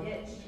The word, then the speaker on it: niche: Niche. – Why niche? – Rhymes with rich.